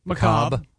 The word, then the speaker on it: macabre: Macabre. Yeah, the R-E silent or whatever.